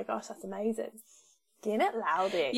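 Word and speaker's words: laldy: I guess it is like Aldi, but with an L in front. So Laldi.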